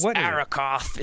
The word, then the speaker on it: Arrokoth: The name is Arrokoth. It's awesome. – I need some music, I think, to make how dramatic... That's right. It's spell that for us. – Arrokoth is spelled A-R-R-O-K-O-T-H.